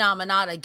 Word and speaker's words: Aminata: So yeah, I think, Queen, how do you pronounce it? – Aminata. That's how they were pronouncing it.